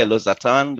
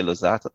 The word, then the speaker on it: Losartan: You know, Germans would say Losartan, the British say Losartan, right?